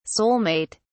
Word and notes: soul-mate: British English

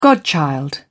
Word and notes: godchild: British English

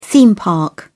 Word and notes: theme park: British English

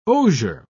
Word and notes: osier: British English

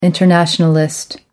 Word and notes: internationalist: American English

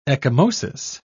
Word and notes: ecchymosis: British English

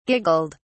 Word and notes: giggled: British English

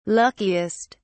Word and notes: luckiest: British English

